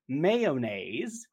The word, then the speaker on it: mayonnaise: It was about mayonnaise, and he makes fun of the way I say it because I guess it's spelled mayonnaise. But from the South, we call it mayonnaise.